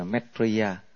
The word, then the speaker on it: Maitreya: Thì Di Lặc, cái chữ đó là phiên âm của chữ Phạn, nó có nghĩa là chữ Phạn nó đọc là Maitreya. Maitreya nó cái gốc của nó là từ.